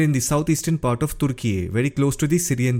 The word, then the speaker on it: Türkiye: Turkey is now pronounced as Türkiye.